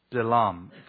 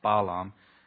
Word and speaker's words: Bilam: I keep mispronouncing Bilam, because in the Hebrew it's the Hirek, so it's the E pronunciation is Bilam, it's not Balaam.